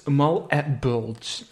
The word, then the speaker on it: Malbolge: pronounced Malbolge staat er hier.